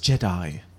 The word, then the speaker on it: Jedha: J-E-D-H-A. – Jedi. – Jedha. – It's pronounced Jedi.